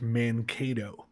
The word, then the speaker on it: Mankato: Uh, it's pronounced Mankato.